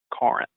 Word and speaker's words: Corinth: Spelled with an O, pronounced with an A. Corinth. Just like you're saying, car.